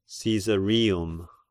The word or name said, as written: Caesarium